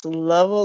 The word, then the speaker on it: Lovell: It's Lovell like shovel.